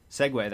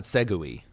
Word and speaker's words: segue: Tell us uh, Is that tell- how you pronounce it? – Segway. That's how, that is, that's it. – Oh, it's not Segui.